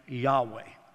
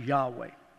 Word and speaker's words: Yahweh: And so this is very appropriate when we think about halal, yah, hallelujah, or Y-H-W-H, we would say it this way, Yahweh. That's what it would be pronounced, Yahweh.